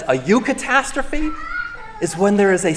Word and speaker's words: Eucatastrophe: And he coined this word, Eucatastrophe. Eucatastrophe. It's on the front of your worship folders to see how it's spelled.